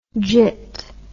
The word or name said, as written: jit